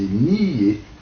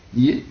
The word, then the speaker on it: nier: donc deux syllabes en ancien français, nier. Mais je vous dis, alors, dans le cours du 16e, 17e siècle, alors, prononciation actuelle qui s'installe, nier une seule syllabe, que les poètes d'ailleurs peuvent compter pour deux syllabes, voyons.